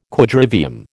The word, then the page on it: Quadrivium: American English